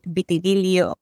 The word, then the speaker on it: vitíligo: ¿Has visto entre la psoriasis, el vitíligo, ¿cómo lo pronuncias? Vitíligo. Vitíligo. Siempre me hago con esa palabra.